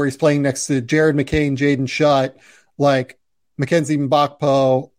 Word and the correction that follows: Mgbako: uh, or Mgbako. Uh, yeah, sorry. Uh, realized I said that wrong the first time.